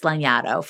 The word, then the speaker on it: Lanyadoo: So nobody can pronounce my name. It's not phonetic. I'm not offended, but also it's Lanyadoo for those who are curious.